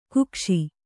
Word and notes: ಕಕ್ಕಶ: kakkaśa

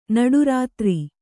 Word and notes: ನಡುರಾತ್ರೆ: Kannada

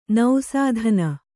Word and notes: ನಿಸೂದನ: nisūdana

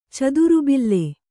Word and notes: ಚದುರುಬಿಲ್ಲೆ: cadurubille

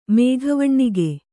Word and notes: ಮೇಘವಣ್ಣಿಗೆ: mēghavaṇṇige

